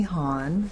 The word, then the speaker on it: Honn: Tracy Hahn, H-O-N-N.